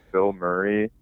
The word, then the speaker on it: Bilmuri: He goes by Bill Murray, but it's spelled B-I-L-M-U-R-I.